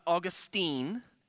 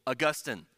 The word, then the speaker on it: Augustine: It's not Augustine, okay? This is St. Augustine, the church saint with a Latin name. And so we're going to honor the Latin and pronounce his name Augustine.